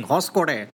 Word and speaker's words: Hoskote: It's in Hoskote, Bengaluru, and I only know how to pronounce that because me and Rhea watched this 25-minute video yesterday where the guy kept saying Hoskote, Hoskote, Don Quixote.